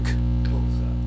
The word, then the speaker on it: Xhosa: It's an X, it's a click. Xhosa.